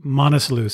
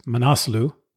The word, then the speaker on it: Manaslu: I think throughout the conversation with Tony, I referred to it as the Manaslu circuit or the Manaslu trek. After talking to Rajan, I realized that it's pronounced Manaslu.